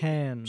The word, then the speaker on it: Cannes: I went to Cannes. Is that how you say it? – Cannes.